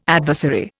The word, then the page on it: adversary: Indian